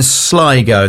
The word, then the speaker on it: Sligo: Now, they formed in Sligo. That is how you pronounce it, isn't it? It's not Sligo, Sligo.